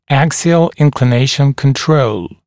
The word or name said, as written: axial inclination control